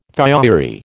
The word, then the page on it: thiourea: American English